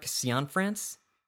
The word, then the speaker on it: Cianfrance: It's a film by, I'm going to mispronounce his name, Derek Cianfrance. – That sounds pretty close.